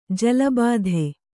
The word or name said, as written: ಜಲಬಾಧೆ